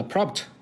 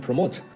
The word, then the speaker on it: prompt: 在第一期这个 prompt 我就读成了 promote，有朋友帮我指正出来了。这个呢，也请大家注意这个发音啊，别被我给带错了。